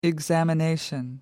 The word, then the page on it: examination: American English